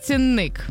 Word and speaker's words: цінник: Окей, перше слово – цінник. – Так. – Я знаю, що там наголос на ік, тому що є віршик «Цінник нікуди не зник».